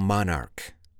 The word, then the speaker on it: monarch: By the way, how do you spell monarch? M-O-N-A-R-C-H. Yeah, the word ends in C-H, but we pronounce it like a K.